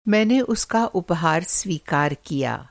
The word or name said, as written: स्वीकारना